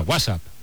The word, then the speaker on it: WhatsApp: No, peor aún, con acento en la primera. WhatsApp, ¿no? – WhatsApp.